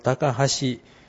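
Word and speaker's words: 高梁: 吉備高原って書いてあるところに高橋、ちょっと高橋って読みにくいですけど、これ高橋と言います。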